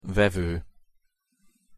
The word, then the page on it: vevo: Hungarian